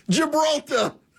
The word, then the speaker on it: Gibraltar: The Hormuz Strait, Malacca, Japan, Gilbertar. Gil, I always say that wrong. Gilbert. You guys know. Gibraltar.